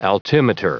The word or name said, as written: altimeter